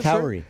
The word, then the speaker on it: Kauri: Kauri.